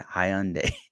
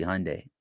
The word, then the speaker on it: Hyundai: Somebody is laughing at me because I pronounced Hyundai wrong because I said Hyundai. It's supposed to be Hyundai.